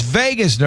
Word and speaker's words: vagus: And one of the things was we talked about the vagus nerve. – No, it's vagus nerve. It's not spelled like the fun Vagus. – V-A-G-U-S.